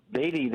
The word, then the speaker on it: Beatty: Am I saying that right? – Yeah, Beatty. That's exactly right, Tom.